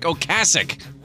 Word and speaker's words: Ocasek: I've found out that's how you say his name, Rick Ocasek.